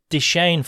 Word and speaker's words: DeChaine: That's the Deschaine family, is that right? Have I pronounced that name correctly? – That's right.